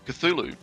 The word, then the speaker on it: Cthulhu: How do you pronounce it then? – Cthulhu. – Ah, well done.